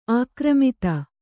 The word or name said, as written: ಆಕ್ರಮಿತ